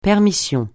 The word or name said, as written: permission